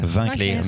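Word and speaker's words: Winckler: Tu l'as dit, c'est chez Le Lombard, de Aude Mermillot, d'après le roman de Martin, comment ça se prononce Vinclair. – Vinclair, merci beaucoup.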